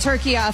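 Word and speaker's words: Türkiye: My entire, I will be saying it turkeya from now on. – And my entire lifetime, I have been, and we have been, incorrectly pronouncing turkey. – It's we've been saying turkeya.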